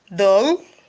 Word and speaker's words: de l': The examples of partitif article are do, dollar, dull, day. Do, dollar, dull, day. Now, do is spelled D-U. Dollar, D-E-L-A. Dull, D-E-L apostrophe. And day, which is spelled D-E-S.